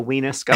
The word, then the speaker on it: Weenusk: Just for my own benefit, how do you spell weenusk? I want you to spell it for me. – It's W-E-E-N-U-S-K.